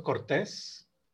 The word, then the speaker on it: Cortés: Sí, me encuentran en Facebook como mi nombre, Alfonso Cortés. Eh, con S. – Con S, sí, con S y con acento en la E.